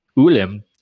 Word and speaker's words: Ulim: So in our flashback episode where, help me pronounce this word, Ulim. – They pretend it's a double O in the audiobook.